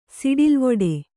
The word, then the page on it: ಸಡಿಲ್ವಿಡಿ: kannada